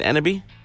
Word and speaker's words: Enneby: IKEA Canada has announced its introduction to sound with the new Enneby line of speakers. Am I pronouncing that right, Enneby? – Yes, you are.